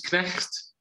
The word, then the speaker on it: Knecht: the actual word that they use is Knecht, which is spelled K-N-E-C-H-T, and it translates to slave.